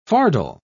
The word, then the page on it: fardel: British English